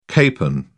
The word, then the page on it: capon: British English